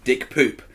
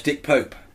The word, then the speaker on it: Dick Pope: And the best thing was the lady who's in charge of the Oscars, I don't know what her job is, mispronouncing the cinematographer of Mr. Turner. – Mr. Turner as Dick Poop. – Isn't that his name? – No, it's Dick Pope.